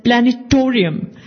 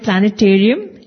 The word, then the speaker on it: planetarium: He said, the word planetarium, don't mispronounce it as planetorium. The correct way to pronounce the word planetarium is terium, not torium, not planetorium.